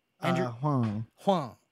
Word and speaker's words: Andrew Huang: How do you say that? – Andrew Huang.